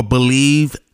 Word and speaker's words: Bleav: Basically, I am the newest podcast host for Believe Network. It's pronounced like Believe, but it's spelled B-L-E-A-V Network.